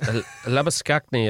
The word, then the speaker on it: Labuschagne: Labuschagne? – Well, it depends. It's Labuschagne if you're from South Africa, but... when you're scoring all these runs, they talked about him being like champagne, so they changed his name to Labuschagne.